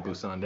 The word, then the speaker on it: Busan: Busan. Yeah. Did I pronounce it? – Yeah, Busan.